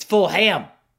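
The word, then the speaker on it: Fulgham: I got a DM from, I think, one of his family members, like, yo, it's Fulham. Travis Fulham.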